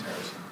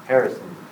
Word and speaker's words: Harrison: First name's Harrison. – What? – Harrison. – As in Harrison Ford. – Mm-hmm. – Yep.